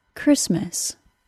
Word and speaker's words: Christmas: It is exactly the word Christmas's Greekness that causes it to be pronounced with a K sound instead of the usual CH sound of English pronunciation.